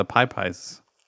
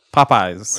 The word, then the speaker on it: Popeyes: And Frank is the only one that's had the Pie Pies. – Pie chicken. Pies? Why'd you say it like that? – Popeyes. – There you go.